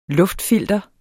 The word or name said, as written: luftfilter